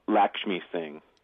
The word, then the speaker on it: Lakshmi Singh: Lakshmi Singh.